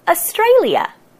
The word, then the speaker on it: Australia: Australia. Australia. We say it with four syllables. A is a bit of a schwa. A, stray, li, a. Same again at the end. Australia.